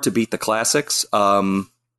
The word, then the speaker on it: Ghidorah: So Ghidorah, I believe, is technically correct from the Japanese pronunciation, but yeah.